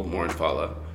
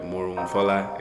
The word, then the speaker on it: Morohunfola: And also I don't make it easy because that's the anglicized way that I teach people, Morohunfola. – Okay. – Rhymes with Bala.